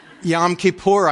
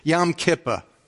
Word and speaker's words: Yom Kippur: And Yom Kippur, I dress in a high priest robe where we share how Christ is pictured in that. Oh, I said that wrong too. Yom Kippur.